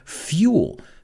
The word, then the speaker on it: fuel: Vamos a pronunciar esa palabra, fuel, you say. Se escribe igual, pero se pronuncia fuel.